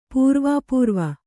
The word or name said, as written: ಪೂರ್ವಾಪೂರ್ವ